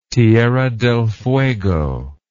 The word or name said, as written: Tierra del Fuego